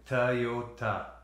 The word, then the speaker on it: Toyota: Oh, it's like the D, right? – Uh-huh. – It's like a D sound. – Toyota.